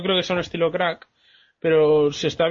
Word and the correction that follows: Crank: bien hecha ah, crank, perdón, lo he dicho mal.